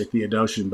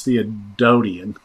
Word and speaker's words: Theodotion: And I want to say Theodotion, but it's Theodotion. It's the pronunciation.